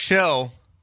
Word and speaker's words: Schell: Mark Schell, which rhymes with go to hell, said I should be automatically disqualified for my first song.